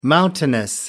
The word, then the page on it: mountainous: American English